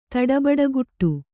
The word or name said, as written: ತಡಬಡಗುಟ್ಟು